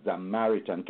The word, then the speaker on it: Zamaritan: So it's actually, I said Samaritan, it's actually Zamaritan.